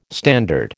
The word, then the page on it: standard: American English